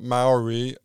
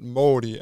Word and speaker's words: mauri: So for people listening, maybe I should make clear, in case my pronunciation isn't very good, which it probably isn't, that what we're talking about now is not Maori, the word for the people, but Māori, M-A-U-R-I.